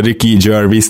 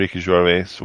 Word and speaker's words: Ricky Gervais: Igen, szenzációs volt Ricky egyébként, azt hiszem Gervais, tehát Ricky Gervais, valahogy így kell kiejteni, mm.